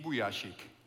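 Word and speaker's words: Vujicic: El apellido se pronuncia de esta forma, tuve que buscarlo en YouTube un video porque es medio extraño, pero así se dice, Nick Vujacic.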